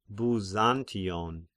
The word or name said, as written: Byzantion